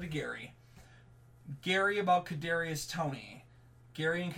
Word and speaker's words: Toney: Toney, yeah.